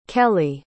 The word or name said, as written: kellee